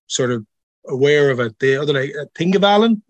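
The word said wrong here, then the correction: Þingvellir: So most people say Tingvellir, like Tingvellir.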